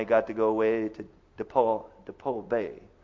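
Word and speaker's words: Depoe Bay: It's not Depot. The locals say it's DePaul Bay, something like that.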